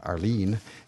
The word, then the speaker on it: Aroline: Her name is spelled A-R-O-L-I-N-E, and it's pronounced Arlene.